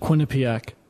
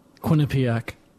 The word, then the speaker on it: Quinnipiac: Quinnipiac. Quinnipiac.